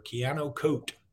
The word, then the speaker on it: Keanu Koht: When we come back, it's time for Keanu Koht. That's how you say it on Jimmy Stein's Countdown.